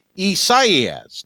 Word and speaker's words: Isaias: All right, so we have Hurricane Isaias.